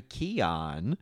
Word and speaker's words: Keoghan: And then I saw that was, and I looked this up and I was like, oh, look at his last name, Barry Keoghan.